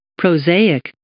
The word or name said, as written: prosaic